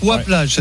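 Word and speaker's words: Oye-Plage: Ouah avec un Y. Ouah Plage, c'est ça? – Oui.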